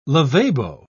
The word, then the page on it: lavabo: British English